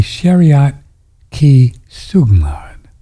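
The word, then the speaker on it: Shariyat-Ki-Sugmad: I said, my Bible is the Shariat Ki Sugmad. I'll spell that for you. S-H-A-R-I-Y-A-T-K-I Sugmad. S-U-G-M-A-D.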